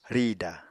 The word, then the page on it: rìda: Apatani